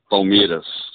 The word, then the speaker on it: Palmeiras: A gente fala Palmeiras a gente engole o I, embora o I exista, a gente não pronuncia esse I em São Paulo, né? Palmeiras.